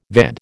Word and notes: VAD: American English

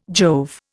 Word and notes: Jove: Indian

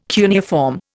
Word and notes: cuneiform: Indian

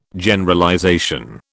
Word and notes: Generalization: British English

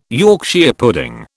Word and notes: Yorkshire pudding: British English

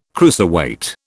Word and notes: cruiserweight: British English

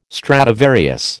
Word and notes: Stradivarius: American English

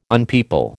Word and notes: unpeople: American English

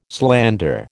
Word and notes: slander: American English

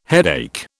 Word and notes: headache: British English

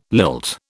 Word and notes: lilt: British English

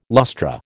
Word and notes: lustra: American English

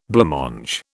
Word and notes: blancmange: British English

